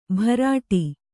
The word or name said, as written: ಭರಾಟಿ